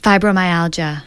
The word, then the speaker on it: fibromyalgia: Anxiety, arthritis, asthma, I might as well go down the list, back or disc problems, depression, digestive disorders, fatigue, fibromyelioma, am I saying that right? – Fibromyalgia, gastrointestinal disorders, headache, hypertension, immune system, infertility, insomnia, labor inducement, mm-hmm.